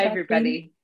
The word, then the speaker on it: Jacqueline: Now, I said Jacqueline's name in the way that Europeans say it because I'm from Germany, so I'm going to let Jacqueline say her name the way it's said in America.